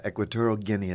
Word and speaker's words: Equatorial Guinea: In English, Equatorial Guinea is how you say it.